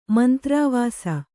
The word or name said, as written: ಮಂತ್ರಾವಾಸ